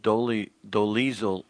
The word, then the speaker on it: Dolezal: Rachel Dolezal kind of rhymes with weasel.